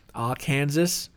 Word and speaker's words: Arkansas: Arkansas.